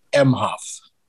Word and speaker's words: Emhoff: No, just I probably overpronounced it. It's just Doug Emhoff.